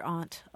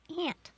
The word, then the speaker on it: aunt: In standard English, there is in African American vernacular English, you hear aunt a lot. Mm-hmm. But in what we call standard English, the general pronunciation in this country is aunt.